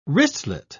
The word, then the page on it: wristlet: British English